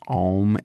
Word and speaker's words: Om: Om is an ancient and sacred word from Eastern spiritual and yogic traditions, including Hinduism and Buddhism. It's pronounced Aum, A-U-M,